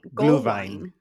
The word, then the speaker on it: Glühwein: So it's also called Gollwein. – Glühwein. – Glühwein. – See, I got that. – That's how you say it in German.